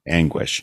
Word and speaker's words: Angwish: And by the way, folks, Anguish is spelled A-N-G-W-I-S-H. Like the last four letters are make a wish on a star, you know, Anguish.